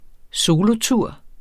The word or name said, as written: solotur